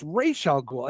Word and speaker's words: Ra's al Ghul: And it's Ra's al Ghul. And it would drive me bananas that they kept saying the name wrong.